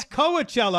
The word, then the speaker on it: Coachella: No, it's Coachella. I like to mispronounce it like Tijuana.